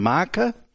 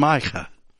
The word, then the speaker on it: Micha: It's spelled differently elsewhere, but here it's spelled M-I-C-H-A, Micah. And that's the way that would be pronounced. It's the back of the throat K instead of the front of the mouth K.